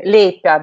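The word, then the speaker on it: leipä: Иначе хлеб по-карельски, финский, лейпя, да, вот то, что я говорила, а с двумя точками наверху читается как йо, а лепить.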